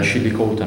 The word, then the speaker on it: Chillicothe: Chillicothe, ha messo, che si pronuncia così, nell'Ohio.